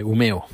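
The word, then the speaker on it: Umeå: Hoy es lunes 28 de junio de 2021 y estoy en, bueno, mejor dicho, estamos, estamos en Humeo, eh, Umeo, pero se escribe Umea y la A tiene un puntito encima, un circulito, y por eso se, se pronuncia O, así que es Umeo.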